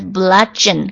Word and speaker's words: bludgeon: 学一个新词 bludgeon，刚刚有念错念成了 bludging，他应该是读作 bludgeon bludgeon B-L-U-D-G-E-O-N-S bludgeon bludgeon 就是表示恫吓或者猛烈攻击棍棒去打这种动词恫吓 bludgeon bludgeon。